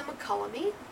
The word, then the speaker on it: Mokelumne: From, I'm going to butcher the pronunciation, Mokelumne. – That's the McCullamy.